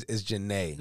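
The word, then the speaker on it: Jhené: Yeah, you had the name wrong. You had Gene. It's Janae.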